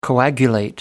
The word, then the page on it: coagulate: American English